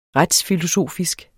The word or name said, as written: retsfilosofisk